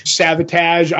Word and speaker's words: Savatage: S-A-V-A-T-A-G-E, I think. – Okay. – So it's like savage, but sabotage kind of a deal.